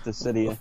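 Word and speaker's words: Dissidia: Dizida? Dizida, yeah. That's how you pronounce it. Dizida.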